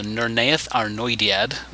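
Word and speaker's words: Nirnaeth Arnoediad: When Túrin was 9 years old, his father left to fight in the epic 5th battle, the Nurnaeth Arnoediad, which is one of my favorite elvish names in the whole series. Took me a while to figure out, but that's how you say it.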